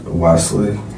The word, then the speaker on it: Wesley: Is Wesley. – How you spell that? – W-E-S-L-E-Y.